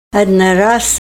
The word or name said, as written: adna ras